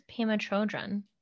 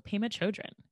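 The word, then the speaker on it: Pema Chödrön: When Things Fall Apart by Pema Chodron. I am probably not saying that right. – That is right. I think it's Pema Chodron. – Oh, Pema Chodron.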